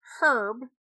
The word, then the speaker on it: Herb: But we had our tour guide, Herb, pronounces the H.